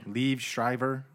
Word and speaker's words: Liev Schreiber: Is it Liev or Levi? – Liev Schreiber. Liev Schreiber.